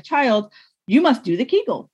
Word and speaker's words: Kegel: The Kegel rhymes with bagel.